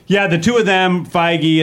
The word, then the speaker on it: Feige: Feige? – Feige.